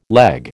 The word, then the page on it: leg: American English